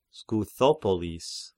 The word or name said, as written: Skythopolis